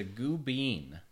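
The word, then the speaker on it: Gubbeen: It's the goo bean.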